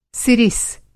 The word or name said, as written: सिरिस